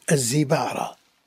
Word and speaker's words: الزبعرى: الزبعرة لا لا لا لا مطوب الكسر لا الزبعرة الزاي مكسورة ابن الزبعرة